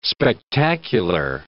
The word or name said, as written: spectacular